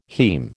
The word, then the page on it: haem: American English